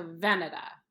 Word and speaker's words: Veneta: I always thought it was like Bottega Veneta. But I think it's not right. – Veneto. – Veneta? – The The emphasis for Veneta is on the first syllable. – So Veneta.